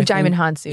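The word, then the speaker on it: Djimon Hounsou: Jimon Hounsou. – Oh, Jimon Hounsou.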